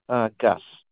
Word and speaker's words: Gus: Gus. – Yes. Can you spell it? – G-U-S.